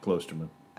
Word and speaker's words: Klosterman: Yeah, Klosterman.